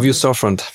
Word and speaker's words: View Storefront: Wu oder Wü, ich weiß gar nicht, wie sie ausgesprochen ist, Storefront, ne? – Genau, View Storefront.